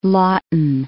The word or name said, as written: Lawton